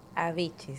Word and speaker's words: Avicii: Waiting for Love, eso es de Avicii, si es que se pronuncia así, o Avicii, A, B, chica, O, B, dentilabial, I, C, I, I. – Avicii, sí.